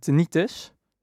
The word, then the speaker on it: tinnitus: Ehm, want Rijer heeft last van tinnitus. Zeg ik dat goed? Ja.